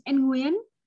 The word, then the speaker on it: Nguyen: Yes, the last name is Nguyen, but not everyone can say Nguyen.